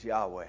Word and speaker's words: Yahweh: This word, again, we don't know exactly how to pronounce it. The majority consensus is Yahweh, to pronounce this Yahweh.